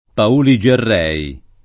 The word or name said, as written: Pauli Gerrei